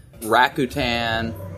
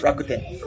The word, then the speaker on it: Rakuten: IBM, Microsoft, Docomo, New York Stock Exchange, Morgan Stanley, Rakuten. – I can tell you're not from Japan. It's Rakuten. Rakuten.